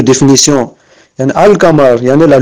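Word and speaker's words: al-qamar: On prononce « al-kamar ». Vous voyez ?« al-kamar ». Il y en a le « lam », ça reste intact. Ça ne disparaît pas dans « kaf ».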